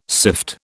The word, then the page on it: sift: British English